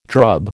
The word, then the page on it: drub: American English